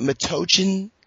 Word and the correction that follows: Metuchen: New Jersey? Metuchen? – Oh, Metuchen. – Metuchen, New Jersey.